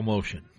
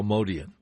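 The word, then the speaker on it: Motian: Here they are, and I always thought it was Paul Motion, but Bill calls him Paul Modian, so that guy's an expert. So I'm going to have to change the way I've been mispronouncing it.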